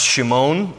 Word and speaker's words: Shimon: Peter was born Simon, that's his Hebrew name, and it would be pronounced Shimon.